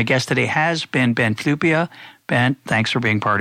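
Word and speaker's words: Flyvbjerg: for listeners, I'm going to tell you that his last name is spelled F-L-Y-V-B-J-E-R-G. I think I got that right. But it's pronounced Flupia,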